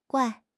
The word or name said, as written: guai4